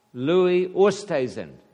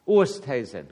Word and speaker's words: Oosthuizen: How do you pronounce his name? – Louis Oosthuizen. Oosthuizen. Oost means east.